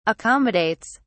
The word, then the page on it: accommodates: British English